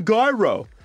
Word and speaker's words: gyro: Because Jax and TJ couldn't pronounce gyro properly. It's a gyro. And I said, I think it's a soft G.